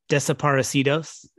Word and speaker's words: Desaparecidos: Desaparecidos.